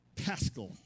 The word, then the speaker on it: Pascal: There was another man named Blaise Pascal. Now, am I saying it correctly? Pascal. Blaise Pascal, a French physicist, mathematician, religious philosopher.